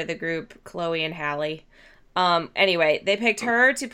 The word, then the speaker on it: Halle: I don't know if you pronounce her name Halle or if it's Hale. It's Halle. It's not Halle Berry. It's Halle Bailey.